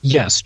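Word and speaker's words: Serlet: How do you say his name? Sirlet. – Just like that.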